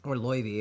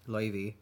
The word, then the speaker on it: Laufey: It's like, this is getting really complex, but his mother is Laufey, who is, you know, her name means, or Loivi is actually how you pronounce it, Loivi.